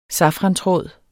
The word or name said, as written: safrantråd